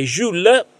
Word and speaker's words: Jules: La lunga relazione tra Nick e Jules, non so se è corretta la pronuncia, come al solito, ha permesso, giusto. – È giusto, grazie,